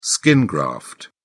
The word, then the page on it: skin graft: British English